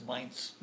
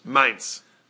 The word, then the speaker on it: Mainz: You're correct in English, but in German it's Mainz. – Mainz.